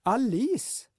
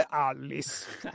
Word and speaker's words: Alice: Hon var själv mycket noga med att andra stavelsen skulle betonas, inte den första. Alice, inte Alice, med vänlig hälsning Jerker.